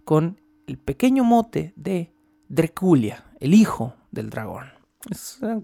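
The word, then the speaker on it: Drăculea: Drácula, que realmente en rumano se pronuncia Dreculia, Dreculia.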